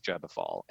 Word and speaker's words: Gebofal: I take the pronunciation from Aaron Leach, which is Gebefall. And if somebody knows the pronunciation, Gebefall.